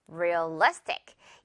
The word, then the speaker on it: realistic: realistic. 이 단어의 발음에서는요. 강세가 중요합니다. realistic. 가운데 있는 이 리 부분을 강하게 발음해주면 돼요.